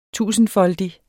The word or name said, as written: tusindfoldig